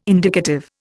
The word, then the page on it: indicative: Indian